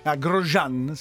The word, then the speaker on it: Grosjean: Però molti altri piloti, stiamo parlando ovviamente dell'incidente pazzesco capitato a, si pronuncia così, Grosjean si pronuncia.